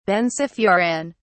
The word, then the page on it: benzofuran: British English